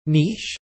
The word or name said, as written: niche